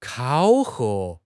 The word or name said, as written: 考核